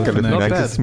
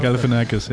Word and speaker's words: Galifianakis: Es spielen unter anderem Hugh Jackman, Zoe Saldana und Zach Galifianakis. So spricht man den aus, oder? Ja, Zach Galifianakis. Mit, Galifianakis, ja. ja.